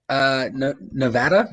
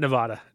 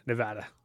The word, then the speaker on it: Nevada: Nevada. – Good. Not Nevada. Nevada. – Yeah. – Just making sure. We have people who get very upset with that. – Now that's the correct way, right? Nevada? – Yes.